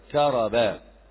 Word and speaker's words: كرب: فكرب، الكاف مفتوحة والراء مفتوحة والباء مفتوح، نطقها كذا كرب.